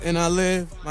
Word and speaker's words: NILIVED: It's NILIVED. My name is Devlin. You spell it backwards.